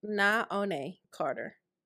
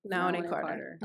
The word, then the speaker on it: Naone Carter: Naone Carter. Naone Carter.